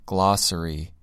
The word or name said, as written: glossary